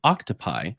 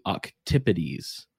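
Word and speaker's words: octopodes: Um, but it uh, people also like to say octopi, which is incorrect because I believe that's like the Latin, putting a Latin ending on a Greek word. And so that's not right. But if you wanted to say it correctly in Greek, it would be octipides, would be the mm. the plural.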